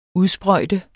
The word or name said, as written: udsprøjte